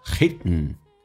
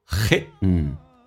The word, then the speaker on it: hidden: OK， hidden D 跟 N 在一起的时候，那么经常会出现 D 会不发音或气流形成阻塞，叫 hidden， hidden， alright，你可以说 hidden，但这个也是对的。